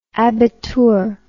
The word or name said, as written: abitur